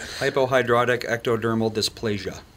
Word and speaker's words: hypohidrotic ectodermal dysplasia: I know Tony Lee asked this morning, but Michael Berryman has hypohidrotic ectodermal dysplasia. – Huh? How was that? – Close. – Okay, how do you say it? – Hypohidrotic ectodermal dysplasia.